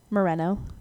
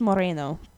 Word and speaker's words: Moreno: Moreno. – Moreno. And that's spelled in the where it's like Marina, like. – It's pronounced Moreno, but because it's Spanish, no people can't say it right.